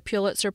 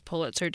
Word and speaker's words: Pulitzer: A few days later, I was talking with another friend about the centenary and complimented him on pronouncing it as Pulitzer did. "Yeah," he said, "I was saying Pulitzer, but somebody corrected me."